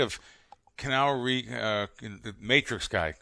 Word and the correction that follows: Keanu Reeves: Am I saying his name right? Kenal Reeves? – Keanu Reeves. – Keanu. That's the way you say Keanu.